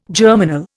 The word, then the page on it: germinal: Indian